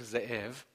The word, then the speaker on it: Ze’ev: Ze’ev.